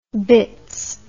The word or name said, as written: bitts